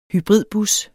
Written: hybridbus

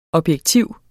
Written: objektiv

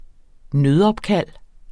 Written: nødopkald

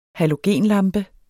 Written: halogenlampe

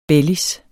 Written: bellis

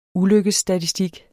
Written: ulykkesstatistik

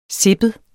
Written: sippet

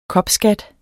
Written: kopskat

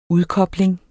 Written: udkobling

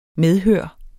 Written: medhør

